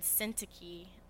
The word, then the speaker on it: Syntyche: And so we encounter these two women, Euodia and Syntyche. That's how you pronounce it.